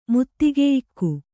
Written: ಮುತ್ತಿಗೆಯಿಕ್ಕು

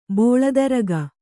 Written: ಬೋಳತರಗ

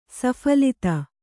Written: ಸಫಲತೆ

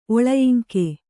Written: ಒಳಯಿಂಕೆ

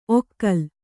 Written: ಒಕಲು

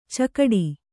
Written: ಚೌಗಡ

